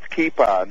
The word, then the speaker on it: kippa: And by the way, it's kippa. That's Hebrew.